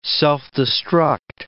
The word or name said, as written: self-destruct